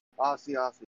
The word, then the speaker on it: Asiasi: How do you say his last name? Azizi? – Asiasi.